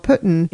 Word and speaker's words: poutine: Which we also learned is not the correct pronunciation. Right. Putin? I'm not sure. – The server told us that it's called Putin.